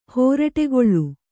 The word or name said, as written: ಹೋರಟೆಗೊಳ್